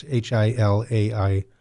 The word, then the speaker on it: Hilaire: There's a couple of good books that have come out recently, but there's also, I think, the works of Hilary Belloc or Hilaire. It's Hilaire. It's a French. I think I would pronounce it Hilary because that's the English pronunciation.